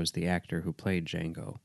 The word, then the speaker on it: Django: And then Jamie Foxx, you know, says Django and then spells it DJANGO and says the D is silent.